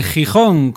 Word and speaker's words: Gijón: Doctor Explosión, con un buen acento en la O, de Gijón, con otro buen acento en la O.